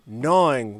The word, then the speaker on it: gnawing: And also, the fantastic Miss Frida gnawing with a silent G on her jackalope antler.